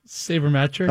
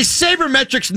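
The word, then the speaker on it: sabermetrics: As a saber matrix nerd that I am. How do you pronounce that word? – Saber. Oh, sorry. Go ahead. – Saber metric. – Okay, as a saber metrics nerd.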